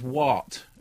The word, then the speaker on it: Waqt: I don't think you pronounce the Q. – Nope. – I think what they said is WAT.